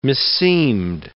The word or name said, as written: meseemed